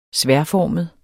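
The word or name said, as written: sværdformet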